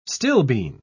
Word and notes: stilbene: British English

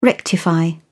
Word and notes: rectify: British English

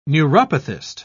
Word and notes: neuropathist: British English

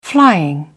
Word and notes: flying: British English

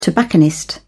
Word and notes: tobacconist: British English

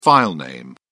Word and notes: filename: British English

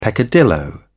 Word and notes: peccadillo: British English

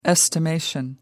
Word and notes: estimation: American English

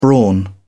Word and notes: brawn: British English

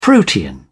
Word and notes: protean: British English

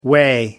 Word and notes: way: American English

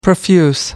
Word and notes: profuse: American English

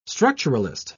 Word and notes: structuralist: British English

